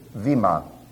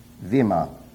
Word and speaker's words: bema: That's B-E-M-A, pronounced in Greek, Vima. That's Vita, Ita, Mi, Epsilon, Alpha, Vima.